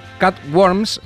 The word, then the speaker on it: Cut Worms: Se llama Cut Worms. Se escribe como cut, como cortar, y worms casi como calores, digamos.